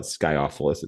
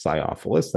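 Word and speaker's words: sciophilous: S-C-I-O, the C is apparently soft. So it's not Sciophilus, it's Sciophilus, thanks to the Oxford English Dictionary for that pronunciation guide.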